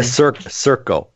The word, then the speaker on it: Sirco: We're talking with Cirro Systems. Did I just say that right? Circo, S-I-R-C-O.